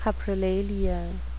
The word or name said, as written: coprolalia